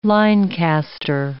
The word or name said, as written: linecaster